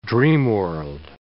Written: dreamworld

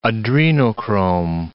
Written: adrenochrome

